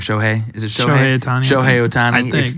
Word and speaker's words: Shohei Ohtani: do they like ship off Mike Trout and Shohei Ohtani too and like rebuild? – Well, if you get a Shohei, or however you say his name, Shohei Ohtani.